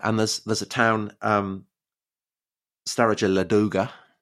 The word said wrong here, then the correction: Staraya Ladoga: Yeah, Staraya Ladoga, Tom. – Is that how you pronounce it? – Your Russian is shocking.